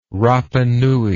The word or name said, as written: rapanui